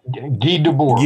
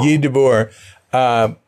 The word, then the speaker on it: Debord: There was a tremendous, the guy, you pronounce his name, to me, he was Guy Debord, but. – Yeah, Guy Debord. – Guy Debord.